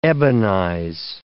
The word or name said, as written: ebonize